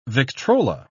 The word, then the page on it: victrola: British English